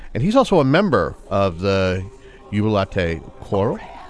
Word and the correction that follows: Chorale: Chorale. – I can start the whole segment over if you guys want. – No, that's okay. Just keep going. – Chorale would be the correct way to say that.